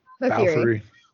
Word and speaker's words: Bofuri: Bofuri.